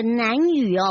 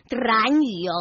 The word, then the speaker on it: 南屿: 嗯，所以这是日月潭的南屿哦，不是兰屿哦，哈哈，请大家哈发音清楚一下哦。